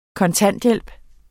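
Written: kontanthjælp

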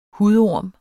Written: hudorm

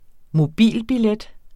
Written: mobilbillet

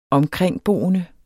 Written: omkringboende

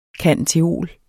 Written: cand.theol.

